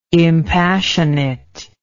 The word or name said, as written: impassionate